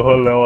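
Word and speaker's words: Rollo: Essa é a pronúncia dele. Fala em português de Portugal mesmo. Rolão. O cara chama ele de rolão.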